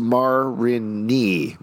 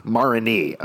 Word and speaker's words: Marigny: A Marigny, pronounced Marigny. Marigny, okay. – Marigny. – Marigny. – Like your what? Like your wife.